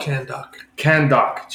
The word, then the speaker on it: Kahndaq: Because I think the summary is that it's Black Adam in Kandarok. Well, damn, what's the city called? – Kandok. – Jesus. – Close. – Dude, the way it's spelled is really strange. – K-A-H-N-D-A-Q. – Kandok. – Yeah. So, Kandok. – Kandok. – Jesus. You're like Khan. – Yeah. – K-A-H-N.